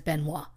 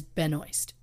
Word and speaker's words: Benoist: Now look, the Canadian in me wants to say her last name is Benoit. – Yeah. – But I went and watched YouTube videos of her saying her name so I could pronounce it the way she wants it pronounced, and she says Benoist.